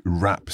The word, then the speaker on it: wrap: And it's worth mentioning the spelling of wrap. We spell it W-R-A-P. So we've got a silent W at the beginning there. – And it's very different from rap, as in R-A-P,